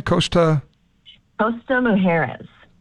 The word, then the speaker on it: Costa Mujeres: Costa Mujeres.